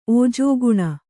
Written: ಓಜೋಗುಣ

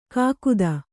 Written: ಕಾಗದ

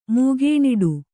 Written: ಮೂಗೇಣಿಡು

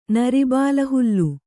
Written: ನರಿಬಾಲಹುಲ್ಲು

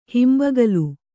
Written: ಹಿಂಬಗಲು

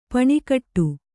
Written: ಪೆಣಗಾಟ

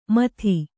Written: ಮಥಿ

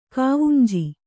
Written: ಕಾವುಂಜಿ

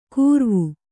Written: ಕೂರ್ವು

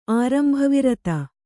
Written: ಆರಂಭವಿರತ

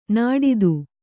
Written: ನಾಡಿದು

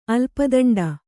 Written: ಅಲ್ಪದಂಡ

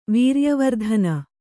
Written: ವೀರ್ಯವರ್ಧನ

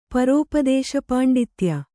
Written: ಪರೋಪದೇಶಪಾಂಡಿತ್ಯ

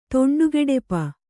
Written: ದಿಂಡುಗೆಡಪು

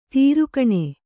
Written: ದೊರೆಗಾಣ್

